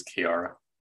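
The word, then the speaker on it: Kiiara: Invisible Heavy, featuring Kiara. Is that how you say it? – Yeah, I believe it's Kiara. – Oh, Kiara.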